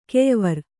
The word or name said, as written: ಕೆಯ್ವಾರಿ